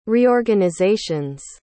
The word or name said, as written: reorganizations